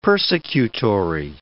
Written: persecutory

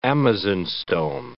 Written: amazonstone

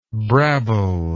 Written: brabbler

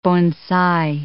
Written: bonsai

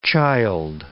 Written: childe

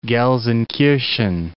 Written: Gelsenkirchen

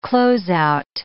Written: closeout

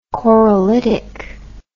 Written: corolitic

